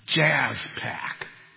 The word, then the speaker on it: JSPAC: The JSPAC, which is pronounced Jazz Pack.